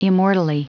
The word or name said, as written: immortally